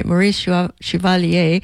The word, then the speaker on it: Maurice Chevalier: Chevalier. I have a nice friend who calls me and makes sure I pronounce it right. Maurice Chevalier.